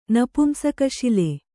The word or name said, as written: ನಪುಂಸಕಶಿಲೆ